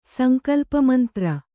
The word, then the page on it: ಸಂಕಲ್ಪಮಂತ್ರ: Kannada